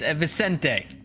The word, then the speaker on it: Vicente: First off, you guys gotta start getting my name wrong. It's Vicente. V-I-C-E-N-T-E.